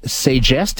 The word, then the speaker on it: Sagesse: We're going to chat with Carrie McManus now, who is the Director of Innovation and Programs at SAGES.